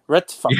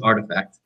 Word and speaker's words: RTFKT: This is, it's called RTFKT. You pronounce that for me. Retfact. – Yeah. The way you pronounce it is artifact.